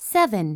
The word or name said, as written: 7